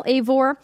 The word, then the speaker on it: Eivor: The hero is named Eivor, and I have Eivor like Cravor because it's actually E-I, so I wanted to get the pronunciation in there.